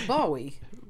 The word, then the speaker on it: Bowie: How do you pronounce the rock star David Bowie. – Oh, Bowie.